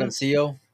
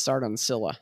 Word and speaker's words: Sardoncillo: Is that how you say his name? Sardoncillo. Yeah. – Yeah, yeah. yeah well, that's like the that. Spanish way to say it, but up in Seattle, it's Sardoncilla, I think.